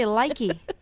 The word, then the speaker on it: Lykke: Well, the way this is spelled, L-Y-K-K-E, there's a band called Likey Lee who spell it the same. Try to say Likey.